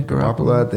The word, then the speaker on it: Garoppolo: Garoppolo, is how you say his name? – Yeah, Garoppolo.